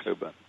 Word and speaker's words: Cockburn: By the way, I should just say, since someone was inquiring about me, anyone try to look me up, remember my name is spelled C-O-C-K-B-U-R-N, Cockburn, and pronounced Coburn.